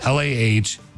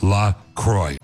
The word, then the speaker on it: LaCroix: So officially it's pronounced LAH-CROY. So it rhymes with enjoy. It's like La Croix and joy. It's not LaCroix. – Yeah, it's not La Croix or La Croix, like the French would say.